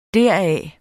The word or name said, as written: deraf